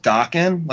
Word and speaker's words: Daken: Well, but, going back to one thing you said, Wolverine's son, his name's pronounced Dokken, like the band? – Mm-hmm. Yeah. – But it's spelled D-A-K-E-N. – Right. – Oh. I would have thought it was Dakin. How, so that's a stat. Have people discussed before how that sounds like D-O-K-K-E-N?